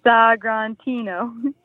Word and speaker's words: Sagrantino: And it says what the wine is, how to pronounce it in like onomatopoeia, Sagrantino.